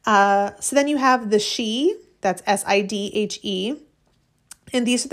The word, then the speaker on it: sith: In Scotland, the word for fairy is S-I-T-H, she, pronounced the same way.